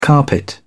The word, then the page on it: carpet: British English